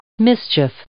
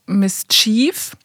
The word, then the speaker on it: Mischief: darüber gesprochen und ich habe äh, Mischief falsch ausgesprochen. Da haben wir nämlich eine nette Mail bekommen äh, von Rose. Ich habe sie jetzt mal englisch ausgesprochen. Sie schreibt: Aloha, checkt bitte mal die Aussprache von dem Wort Mischief und dann äh, so ein Link vom, äh, von, einem, von einem Dictionary. Mischief. Und äh, ich habe wohl gesagt, Miss Chief.